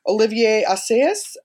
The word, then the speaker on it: Olivier Assayas: And it also has made me really view some contemporary directors, particularly Olivier Assayas. I'm not sure if I'm saying his name right. – I believe you are.